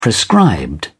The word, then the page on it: prescribed: British English